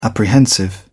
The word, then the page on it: apprehensive: British English